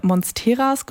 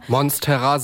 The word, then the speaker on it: Monstera: Ich habe da sehr oft über meine Monsteras gesprochen, die ich glaube ich immer noch falsch ausspreche. – Monstera sagt man Monstera.